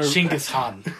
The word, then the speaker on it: Chinggis Khan: Genghis Khan. – We haven't seen her. – Chinggis Khan. That's actually how it's actually pronounced. Chinggis Khan.